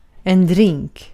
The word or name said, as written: shrub